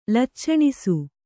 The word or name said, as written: ಲಚ್ಚಣಿಸು